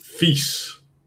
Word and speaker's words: vies: Vise. – Vise. – So it's the E and the E difference. So vise for filthy, for gross, and vis, E for fish.